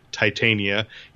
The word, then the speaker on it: Titania: they pronounce her the archenemy's name as Titania, even though that's probably nowhere near the actual accuracy in ancient Greek.